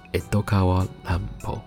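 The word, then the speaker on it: 江戸川乱歩: 那江户川乱步的日文发音叫做 Edokawa Lanpo。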